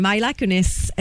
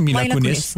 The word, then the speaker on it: Mila Kunis: Ashton Kutcher et Myla Kunis. Est-ce que je le prononce bien? – Kunis, je pense hein? que Mila Kunis.